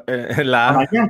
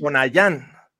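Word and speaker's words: Tonayán: Me, di cuenta que se pronuncia Tonjan porque tiene acento en la. – Tonayan, claro, sí. Tonayan.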